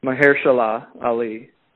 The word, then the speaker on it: Mahershala Ali: I believe that's the pronunciation is always a little tricky for me, but Mahershala Ali.